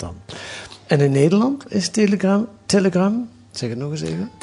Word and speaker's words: Telegram: Telegram. Zo moet ik het uitspreken. – Telegram. – Een beetje op zijn... – Op zijn Russisch.